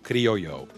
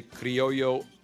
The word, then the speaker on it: Criollo: and I'm talking about the Camacho Criollo. And it is spelled C-R-I-O-L-L-O.